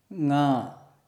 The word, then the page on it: ṅ: Sanskrit